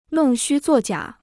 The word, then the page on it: 弄虚作假: nòng xū zuò jiǎ